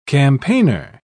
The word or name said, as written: campaigner